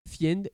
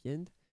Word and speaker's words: Fiend: Bring It On Down, Intergalactic, 645, Fiend, et pas Friend, je n'ai pas mal prononcé, donc Fiend, Entering Orbit, visiblement, ils ont un truc avec l'espace, quand même.